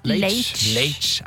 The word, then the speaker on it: Leitch: Leitch. – Jeg må lære meg uttalen på det navnet. David Leitch.